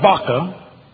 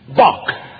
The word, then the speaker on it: Bach: Now, this is not Baca. It's simply Bach, with a silent Shiva there, for you scholars.